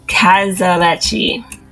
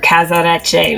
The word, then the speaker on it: casarecce: It's C A S A R E C C E. Casarecci. Or Casarecce, maybe. Casarecce.